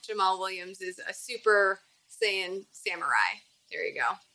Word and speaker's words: Saiyan: Am I saying that right? Saiyan. – Super Saiyan. – Super Saiyan. There you go. – You were almost like seance there, which is a different genre.